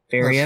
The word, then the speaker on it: Faeria: It's Faria, if I'm pronouncing that right. F-A-E-R-I-A. Faria. – That's how I would pronounce it.